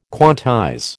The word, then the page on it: quantize: American English